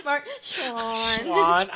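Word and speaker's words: Schwahn: Mark Schwann. – I'm never sure if I'm saying it right. – You're saying it right.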